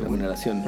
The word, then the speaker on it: remuneración: pero tienen un proceso y una curva como de remonetas, ¿cómo se dice? Remuneración.